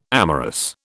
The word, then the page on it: amorous: British English